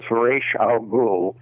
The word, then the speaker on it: Ra's al Ghul: My daughter asked a professor at UCLA that question about 20 years ago, and I think what she got was Ra's al Ghul.